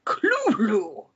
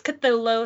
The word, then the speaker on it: Cthulhu: The actual sound, as nearly as any human organ could imitate it or human letters could record it, may be something like Clulu. The first syllable pronounced gutturally and very thickly. The U is about like that in full, and the first syllable is not unlike the Clul in sound, hence the represents the guttural thickness. Cthulhu. But, you know, we say Cthulhu, because fuck it.